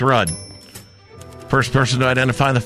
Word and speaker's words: Rudd: I thought it was Rude. – Is that how you say it? It's Rudd.